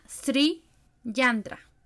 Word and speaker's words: Sri Yantra: Em, y bueno, hay otro, que, hay otro yantra, que este está un poquito difícil de, de pronunciar, que se llama Sri Yantra. S-R-I Yantra.